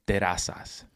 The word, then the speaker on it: Terrazas: And to help me address this hard saying, I am joined by my faithful friend, Adam Terrazas. How are you, bro? – Good. And you pronounced that perfectly.